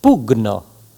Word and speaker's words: pugno: De même, G plus N se prononce gn comme dans diagnostic et non pas gne. Exemple, pugno, digna.